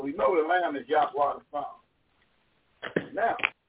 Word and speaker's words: Yahweh: His name is YHWH. And how you properly pronounce it, Yahweh.